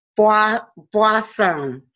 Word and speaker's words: Boisson: Today's show topic is helping parents heal with Elizabeth Boisson.